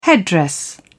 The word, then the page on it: headdress: British English